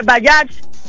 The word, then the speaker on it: Bajaj: Oigan, y se escribe Bajaj, ¿ok? Pero se dice Bayash.